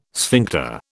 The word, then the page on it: sphincter: British English